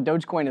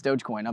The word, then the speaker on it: Dogecoin: I think it's Dogecoin. I've always been told Dogecoin. I could be wrong, but that's what I've always told. I'm going with it, Dogecoin.